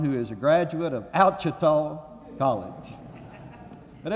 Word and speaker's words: Ouachita: But it's spelled O-U-A-C-H-I-T-A. It's an Indian name, and it's on the Ouachita River.